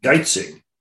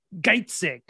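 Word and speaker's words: Geizeg: And how do you say this villain's name? – Geitzig. – Geitzig. I wasn't quite sure about that. It's G-E-I-Z-E-G.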